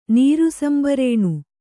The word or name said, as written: ನೀರುಸಂಬರೇಣು